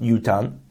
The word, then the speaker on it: yutan: Now, the thing is, it's pronounced yutan, but it's written yitan without a vav.